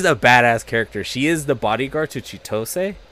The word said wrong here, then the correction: Chitoge: and my god, Chitoge. I keep getting her name wrong. Yes, Chitoge.